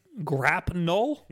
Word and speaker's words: grapnel: Batman grapple gun, which I guess. – Grapnel gun. That's the official pronunciation. Grapnel. There's an N in there.